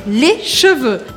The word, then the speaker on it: cheveux: Para falar no plural, les cheveux, les cheveux. E aqui mais uma dica de pronúncia, porque é diferente les cheveux, os cabelos, de les chevaux, os cavalos.